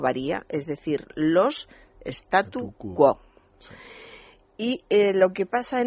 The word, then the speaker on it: statu quo: Es muy habitual la confusión decir status, acabado en ese, status quo, en vez de lo que se debe decir que es statu quo. – Y quo con, acento sin tilde, pero recayendo el acento en la O.